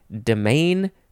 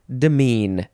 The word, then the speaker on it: demesne: The next word is demean or demean. This is spelled D-E-M-E-S-N-E. Demean, demean.